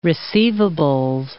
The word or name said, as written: receivables